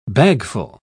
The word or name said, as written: bagful